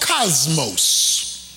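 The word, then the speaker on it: cosmos (κόσμος): The Greek word for the word world is pronounced cosmos.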